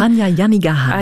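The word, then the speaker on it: Hanya Yanagihara: Hanya Yannigahara.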